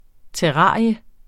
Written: TERRARIER

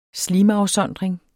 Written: slimafsondring

